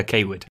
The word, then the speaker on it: Cawood: It's pronounced uh, Kaywood.